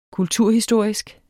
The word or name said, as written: kulturhistorisk